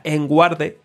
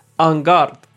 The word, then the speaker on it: en garde: Y luego otra curiosidad también llamativa es que el nombre del pez espada, que antes mencionábamos que no sabíamos cómo pronunciarlo, yo de pequeño decía enguarde, literalmente como se lee, pero viene de una expresión francesa que es en garde, que me perdonen porque no controlo mucho yo de francés, pero significa en guardia y la usan en el esgrima.